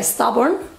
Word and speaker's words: stubborn: Cuidado al pronunciar, no pongáis E al principio, ¿eh? Es stubborn. Stubborn.